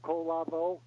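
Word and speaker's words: Kolavo: You can just type in my name, Craig Calavo. It'll take you to the author's page. The last name is spelled K-O. L-A-V, like victory, O, Colavo.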